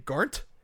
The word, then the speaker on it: Garnt: Garnt.